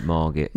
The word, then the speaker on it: Margate: Say it like that, Margate.